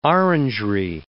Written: orangerie

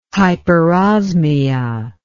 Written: hyperosmia

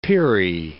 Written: peri

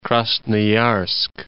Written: Krasnoyarsk

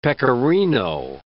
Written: pecorino

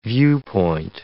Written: viewpoint

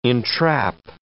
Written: entrap

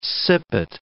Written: sippet

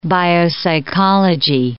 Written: biopsychology

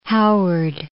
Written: Howard

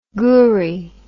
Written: goorie

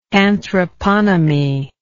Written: anthroponomist

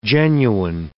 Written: genuine

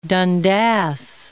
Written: Dundas

